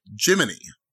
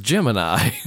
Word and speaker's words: Gemini: Oh, so, but the weirdest part, having seen First Man now, is it's Jiminy, not Gemini. – Well, it's everyone pronounced it Jiminy at the time. – Yeah. – But it's... – The word is Gemini.